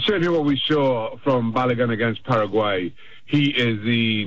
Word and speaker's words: Balogun: There's a young man, I believe you pronounce his name, Florin Balogun. Did I say that right, or am I messing up that name? – Balogun.